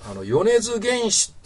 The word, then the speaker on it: 米津玄師: 米津玄師って読むんですかね、これ。